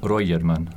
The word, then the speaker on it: Röjerman: Jonas Röjerman.